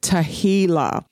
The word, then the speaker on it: Tehillah: Now, there are a few other words, but the last word that I want to talk about is the word Tehillah. It is spelled T-E-H-I-L-L-A-H, and it is derived from the word Hallel, which means the singing of Hallels.